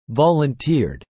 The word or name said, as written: volunteered